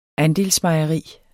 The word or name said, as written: andelsmejerierne